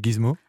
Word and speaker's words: Gizmo: Vous êtes le fondateur de, on dit Gizmo? – Oui, Gizmo. – Alors ça s'écrit G-I-Z-M-O parce que sinon on va m'écrire pour me demander.